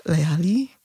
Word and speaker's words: Layali: Her debut album is called Layali. Did I say it right? – Yeah.